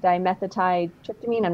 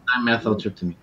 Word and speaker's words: dimethyltryptamine: Our body naturally has DMT, dimethyltryptamine. I'm not saying that right. In it, and dimethyltryptamine.